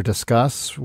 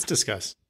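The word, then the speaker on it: Disqus: So I've got my comments now as, I'm just going to call it Discus because I think it looks like Discus. – It's Discuss. – It's Discuss. Dang it. Okay. I've been pronouncing it wrong this whole time.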